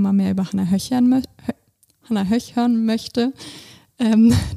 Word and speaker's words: Hannah Höch: Werde ich auf jeden Fall mal reinhören und Respekt an dich, dass du Hannah Höch hören möchtest.